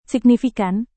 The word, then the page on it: signifikan: Indonesian